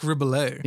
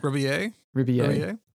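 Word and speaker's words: Rebillet: how do you say his name? – Oh, Mark Ribollet. – Yeah. – Ribollet.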